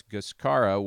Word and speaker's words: Gushkara: I said that wrong earlier. That's Gushkara with a G.